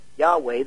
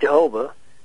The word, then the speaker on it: Yahweh: So God our Father, Yahweh, the living God, the personal covenant God, by which we know his name, not Jehovah. That's the English mispronunciation that comes in later.